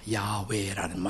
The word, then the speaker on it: 야훼: 자, 여호와라는 말은 본래 히브리식 발음으로 하자면 야외입니다. 야외라는 말.